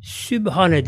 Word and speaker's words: Sübhane: Arapçada P harfi yoktur. Bu B'dir bu. Yine bazıları sub yapıyorlar, kalın okuyorlar. O zaman sat olur, anlam değişir. Mesela sub, sab anlamına gelir. İnce sindir ve B'dir. Sübhane diye okunur.